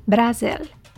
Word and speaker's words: Brasil: First, let's start with Brazil, pronounced Brasil in Portuguese.